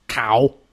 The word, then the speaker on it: Quao: I think I got it as a part of the card game that's right by your left leg called Cow. – Okay. – Oh, man bites dog. – Cow. – Cow. – Cow. Cow. – I was looking for C-O-W. – Cow is spelled Q-U-A-O. Pronounced cow.